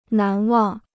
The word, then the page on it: 难忘: nán wàng